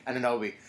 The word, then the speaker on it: Anunoby: There is so much talent in this draft, and the Raptors got an absolute steal because even though Anunoby. I can't even say his name. – Anunoby.